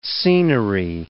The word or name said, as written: scenery